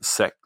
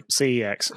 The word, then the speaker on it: CEX: If you get stuck with a game, just stick it in the bin, or put it on eBay or take it to SEX. Is it CEX? I don't know how you pronounce that. You don't pronounce it SEX then. – No, CEX. – Okay, thank you.